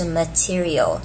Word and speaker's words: material: OK, material 这个字呢，请注意中间这个 T-E-R 不是念 ter，它是念 T，所以是 material，所以要注意重音在 T 身上，所以是 material，的的的的 material。